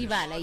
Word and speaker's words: Idibala: Maybe you took in the, what am I saying it right? Idibala challenge. – Idibala, yes. – Idibala challenge.